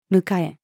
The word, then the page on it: 迎え: female